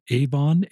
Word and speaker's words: Avon: He grew up in Avon, A-V-O-N. Is that how I pronounce it? – Yep, like the makeup company, yep.